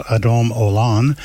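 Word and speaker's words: Adon Olam: Lord is pronounced Adam Olan.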